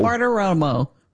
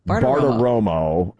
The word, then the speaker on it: Bartiromo: Bartiromo, Romo, Bartiromo. – Bartiromo. – Bartiromo.